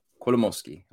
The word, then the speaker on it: Kolomoisky: Kolomoisky.